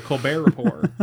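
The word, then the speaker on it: Colbert Report: Wednesday was a show called The Lapore Rapport, which was a play off the Colbert Rapport with a silent T.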